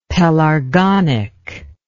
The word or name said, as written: pelargonic